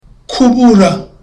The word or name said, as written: kubura